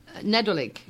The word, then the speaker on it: Nadolig: Nedolig.